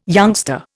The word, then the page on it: youngster: Indian